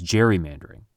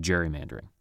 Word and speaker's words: gerrymandering: And although the cartoonist was probably using a hard G to evoke Gary's surname, the term stuck. We now use gerrymandering to refer to maps we feel are, for one reason or another, unfair.